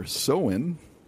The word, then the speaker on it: Samhain: And the festival back then, I'll pronounce it here, Samhain is how it's pronounced, spelled Samhain.